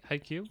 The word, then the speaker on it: Haikyuu: Haikyuu, am I saying that right? Haikyuu. Yes, Haikyuu. Yes, sir.